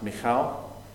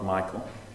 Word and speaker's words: Michal: Let me just say, her name is pronounced Michal, not Michael. I've heard people pronounce it Michael.